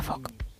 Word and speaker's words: folk: Su regla clave es que la letra L es muda. – Anda. – Con lo cual se pronuncia folk.